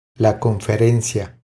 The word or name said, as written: La conferencia